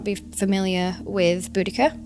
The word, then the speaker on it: Boudica: In our London version, in the Tilted London, Boadicea, or Boudica, she's more commonly known, Boadicea is the Victorian pronunciation.